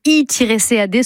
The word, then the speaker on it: I-CAD: La base de données I-CAD se prononce ICAD.